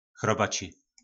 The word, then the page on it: chrobaci: po nasimu